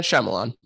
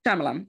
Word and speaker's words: Shyamalan: Shyamalan? That's how you pronounce it, isn't it? – M. Night Shyamalan. – Shyamalan. Okay, thank you.